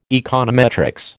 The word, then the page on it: econometrics: American English